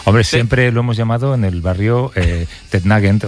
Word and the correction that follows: Nugent: Pero la pronunciación correcta es Nugent. – Te fuiste a Washington y descubriste que era Nugent.